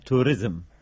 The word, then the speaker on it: توریزم: نظر وای کا سوال وای یو بس، دا تروریزم په مناسب خبری دی کنه؟ – د تروریزم نه، د توریزم. بلنیش تا تلفظ که فرق تا خیره.